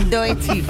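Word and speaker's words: Doechii: Doechi.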